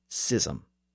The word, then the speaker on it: schism: The new spelling was S-C-H-I-S-M. In the 1700s, scholars started to argue that the word should be pronounced schism instead of schism based on the original Greek and Latin pronunciations. In other words, it should mirror the pronunciation of words like school and scheme.